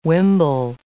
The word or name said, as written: wimble